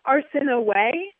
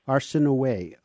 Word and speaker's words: Arsinoe: So first of all, tell us what the correct pronunciation of Arsinoe is. – It's Arsinoe. – Arsinoe, okay.